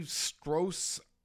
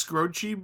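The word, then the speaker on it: Skroce: Anyway, it's this book by Steve Strohs. Is that how you pronounce my man's name? I never know. – Gee, I think it's Scroce, but I'm not 100% sure.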